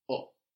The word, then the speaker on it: ho: I have in Italian is just o. O. Just o. And this is spelled H-O. So this H is silent, like we saw in umanità. No? H-O-O.